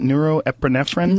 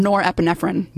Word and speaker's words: norepinephrine: Norepinephrine? – Norepinephrine.